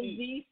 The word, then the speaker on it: Thee: Her name is actually Megan Thee Stallion, not The Stallion. – That's right. – Two E's. B-H-T-E. Megan Thee Stallion.